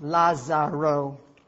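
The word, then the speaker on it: Lázaro: His name is Lazarus, pronounced Lazaro.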